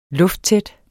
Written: lufttæt

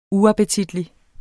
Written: UAPPETITLIGT

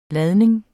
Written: ladning

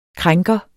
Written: krænker

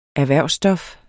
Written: erhvervsstof

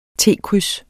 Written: T-kryds

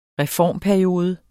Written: reformperiode

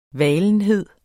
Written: valenhed

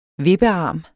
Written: vippearm

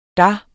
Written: der

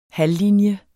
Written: halvlinje

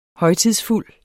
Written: højtidsfuld

